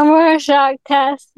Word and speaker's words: Rorschach: How do you pronounce that? – Yeah. A Rorschach test.